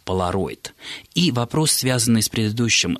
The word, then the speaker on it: поляроид: Слово «полароид», все-таки произнесу так, хотя написано «поляроид», существует в нашем языке с довоенных времен. Оно в соответствии с правилами словообразования, считает Борис Васильевич, и по аналогии с словами «полярный», «полярник», «полюс» писалось и произносилось только как «поляроид».